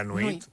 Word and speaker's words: nuit: La nuit.